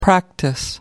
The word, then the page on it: practice: American English